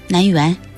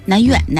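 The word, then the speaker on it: 南苑: 这是咱老北京发音，发音呢别给误导了。 – 对，咱们就按照应该发的音，南园、南院、南院，你看我都倒腾不过来了。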